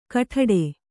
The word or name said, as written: ಗೊಡ್ಡು